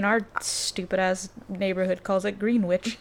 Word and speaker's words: Greenwich: I didn't know it was Greenwich until studying music from New York. – Oh. – And then the teacher was like, yeah, it's Greenwich. And I was like, what the fuck? I've been saying this wrong, like, for 20 years.